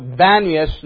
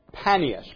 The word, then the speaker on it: Banias: And the Arabic pronunciation for the word pan, Arabic has no P sound. So it comes out Banias, not Panias, because Arabic speaking people can't say the P sound.